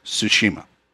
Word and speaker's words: Tsushima: The correct American pronunciation of it is Tsushima.